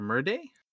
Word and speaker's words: Merde: And the last name is spelled Merde, like M E R D E, kind of like like verde in Spanish, green.